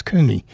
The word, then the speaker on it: Coone: And that was Coon, C-O-O-N-E, often pronounced as Cooney, Fragile State.